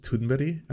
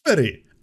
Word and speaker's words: Thunberg: Thunberg! Ah! Wow, of course Amy was on point.